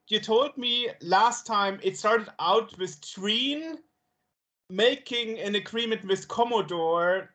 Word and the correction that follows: Drean: Drean is, we pronounce here Drean. Because I don't know what it means, because it's not Dream, it's Drean with an N.